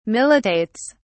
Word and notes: militates: British English

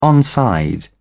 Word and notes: onside: British English

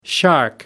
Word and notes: shark: American English

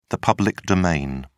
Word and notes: public domain: British English

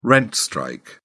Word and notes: rent strike: British English

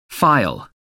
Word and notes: phial: American English

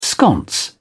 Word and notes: sconce: British English

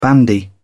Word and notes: bandy: British English

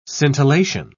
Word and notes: scintillation: British English

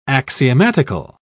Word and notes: axiomatical: British English

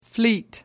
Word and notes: fleet: American English

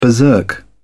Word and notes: berserk: British English

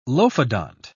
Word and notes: lophodont: British English

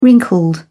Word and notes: wrinkled: British English

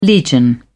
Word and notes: legion: British English

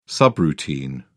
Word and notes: subroutine: British English

